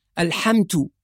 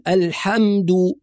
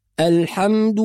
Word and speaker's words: الحمد: أيضا البعض يحول الدال إلى تاء فيقول الحمد، كأنها من اللحام وهي دال وليست تاء، والدال قريبة جدا في المخرج من التاء. إذن على القارئ أن ينتبه إلى نطق الدال الصحيح. يبقى نقول الحمد دو وليست الحمد.